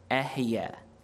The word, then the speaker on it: Ehyeh: The Hebrew word translated as I am in your Bible is pronounced "Ehyeh," and it means I am or I will be.